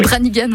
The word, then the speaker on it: Branigan: Comment vous dites, Branigan? – Oui. Branigan, oui, voilà.